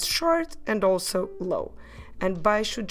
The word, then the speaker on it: baixo: I repeat, baixo.